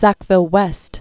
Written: Sackville-West

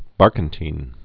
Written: barquentine

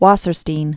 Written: Wasserstein

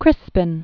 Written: Saint Crispin

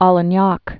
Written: Olenek

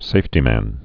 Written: safetyman

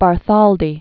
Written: Frederic Auguste Bartholdi

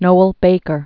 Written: Philip John Noel-Baker

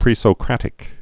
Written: pre-Socratic

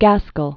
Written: Gaskell

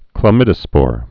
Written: chlamydospores

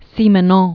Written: Simenon

